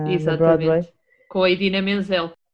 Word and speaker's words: Idina Menzel: É a Idina Menzel, diz da mesma forma que se escreve.